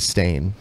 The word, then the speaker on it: Berenstain: Plus, I mean, if you read it, like, if you look at the book, it's always Stain. It's spelled S-T-A-I-N. – It used to be the Berenstain Bears.